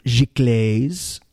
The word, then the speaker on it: giclée: including giclées. That's literally, that's how you say it, apparently. Giclées and lithographs. – Galicious.